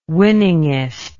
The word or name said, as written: winningest